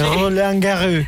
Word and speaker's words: Roland Garros: Roland Garros.